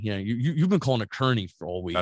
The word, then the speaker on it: Kearney: It's called Kearney. K E A R N E. – When you spell it out, it sounds like Kearney. E A makes that E. – Kearney. – Kearney.